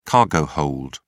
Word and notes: cargo hold: British English

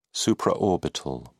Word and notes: supraorbital: British English

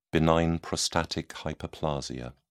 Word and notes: benign prostatic hyperplasia: British English

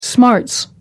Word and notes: smarts: American English

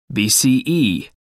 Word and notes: bce: American English

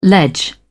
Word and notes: ledge: British English

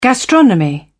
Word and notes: gastronomy: British English

